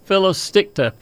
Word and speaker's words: Phyllosticta: It's called Phyllosticta, P-H-Y-L-L-O-S-T-I-C-T-A.